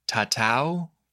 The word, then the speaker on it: tatau: Tahitian people use the word tatau. The anglicized spelling is T-A-T-A-U. But tatau, or maybe pronounced closer to tattoo, that was a verb in the Tahitian Polynesian language, which means to strike or to mark.